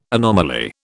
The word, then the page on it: anomaly: British English